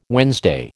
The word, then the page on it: Wednesday: American English